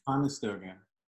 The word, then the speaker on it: Conestoga: The Conestoga, is that how you say it, Cowboys? – Conestoga. Conestoga, Conestoga the OG Conestoga Cowboy right here.